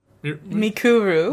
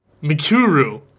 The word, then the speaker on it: Mikuru: Oh, it hit it every time. Miruku? – Mikuru. – Miruku? Mikuru. God Japanese names are hard to pronounce.